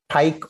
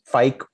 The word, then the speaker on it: PHEIC: For them, what is important is the declaration of a PHEIC, which is pronounced pike or fike or fake or other, which is a public health emergency of international concern.